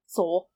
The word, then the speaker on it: Seoul: It's just Seoul, right? – Seoul. Yeah. I'm sure they have a better accent in a different way, but if you're American, it's Seoul. – Okay. Flashback to Seoul, where we see Sun's dad promoting Jin to his special assistant.